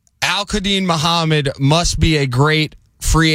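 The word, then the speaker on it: Al-Qudeen Muhammed: That is Al-Quadeen Muhammad. – Al-Quadeen Muhammad. A-L-Q-U-D... E-E-N. – Oh, you can just ding him now. – M-U-H-A-M-M-E-D.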